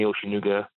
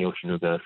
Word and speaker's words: Oshinuga: But for the listeners out there, you can definitely follow me on Instagram, Anthony Oshinuga, or Facebook under Anthony Oshinuga. So my last name is spelled Oshinuga, Anthony Oshinuga.